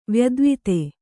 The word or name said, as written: ವ್ಯದ್ವಿತೆ